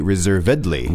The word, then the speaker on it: reservedly: But the pronunciation, as you said, changes when you change it to an adverb. – Mm. – So we say reservedly.